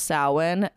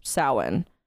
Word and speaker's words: Samhain: It's spelled Samhain, but it's pronounced Samhain. And the, I believe, pagan people who have taken over the Celtic rituals, they do not like it when you mispronounce their words, so it's Samhain.